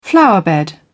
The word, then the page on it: flower bed: British English